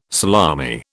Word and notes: salami: British English

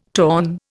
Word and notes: torn: Indian

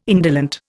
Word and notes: indolent: Indian

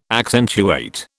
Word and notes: accentuate: British English

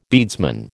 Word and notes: beadsman: American English

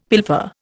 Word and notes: pilfer: Indian

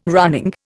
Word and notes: running: Indian

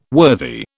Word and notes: worthy: British English

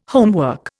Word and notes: homework: Indian

